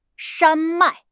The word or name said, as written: 山脉